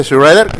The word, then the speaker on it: Shredder: Y ya busqué la pronunciación de Shredder y sí lo estaba diciendo bien.